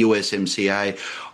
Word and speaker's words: USMCA: which was the USMCA. I always like to think it rhymes with YMCA, yeah, the song.